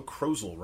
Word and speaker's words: Krozel: We traded some emails last week with our friend Joe Krozel, rhymes with puzzle.